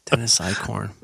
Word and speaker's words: Eichhorn: Is that I-K-E? – E-I-C-H. – Mm-hmm. It's pronounced Jaden. Dennis Eichhorn.